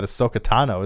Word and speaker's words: Ahsoka Tano: But there was some incredible Star Wars news yesterday, side tangent, that Ahsoka Tano is going to be, probably going to be in the second season of Mandalorian.